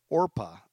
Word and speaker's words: Orpah: but her first name is actually Orpah, O-R-P-A-H. Orpah Gail Winfrey was on her birth certificate after the biblical figure in the book of Ruth, but so many people mispronounced it regularly, and so Oprah stuck.